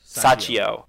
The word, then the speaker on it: Sachio: Let's talk about we kind of like teased Sachi. Yeah. Is that how you say it? – Sachio. – Sachio.